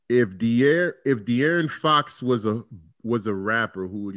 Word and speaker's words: De'Aaron Fox: De'Aaron Fox.